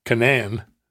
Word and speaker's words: Conan: Or Conan. Conan. As we say, that's the canonical pronunciation.